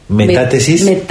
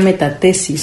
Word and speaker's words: metátesis: Muy bien, pues entonces metátesis. – Con acento en la A. – Ajá, metátesis. Uh-huh. Sí, porque si no sería metatesis.